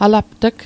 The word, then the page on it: alapt'g: Mi'gmaq